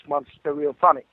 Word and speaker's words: Monster Stereophonic: It's like it's a combination of two words, Monster and Stereophonic, so it's Monster Stereophonic.